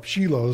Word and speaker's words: Shiloh: He's called Shiloh, or Shiloh is the right way to pronounce it.